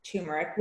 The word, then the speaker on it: turmeric: And I love that you said turmeric, which, by the way, is someone who does and pronounced it correctly. And pronounced it correctly for those out there listening.